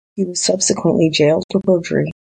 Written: jailed

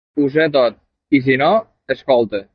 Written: escolta

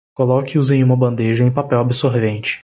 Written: bandeja

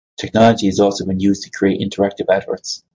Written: adverts

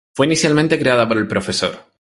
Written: creada

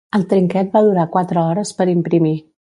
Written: trinquet

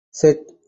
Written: Set

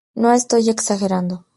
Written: estoy

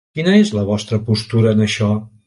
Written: vostra

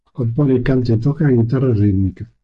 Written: canta

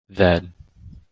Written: Then

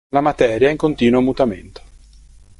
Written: materia

